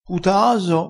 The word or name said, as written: putaaso